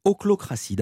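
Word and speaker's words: ochlocratie: Ah bah tiens, alors, puisque vous parlez qu'il faut qu'un mot soit parlé, un mot qui m'a étonné, oclocratie. D'abord, qu'est-ce que l'oclocratie? Ça s'écrit O-C-H-L-O, puis ensuite cratie comme démocratie.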